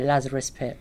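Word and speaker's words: Lazarus Pit: Now, Red Hood, he was Batman's second Robin, and he got killed by the Joker, but he was resurrected years later by Lazarus Pitt. Am I saying that right? – Yes.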